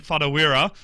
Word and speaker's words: Whatuira: Paul, I'm probably going to butcher the last name, Whatuira.